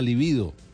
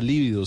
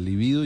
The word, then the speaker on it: libido: pero el impulso sexual es la libido palabra grave con, la, con el acento en la segunda sílaba, libido. ¿Sí? Y no en la primera libido sino en la segunda, libido y se escribe con B larga.